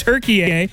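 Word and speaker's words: Türkiye: It's crazy that we pronounced it Turkey for the longest time, but it's Turkey A.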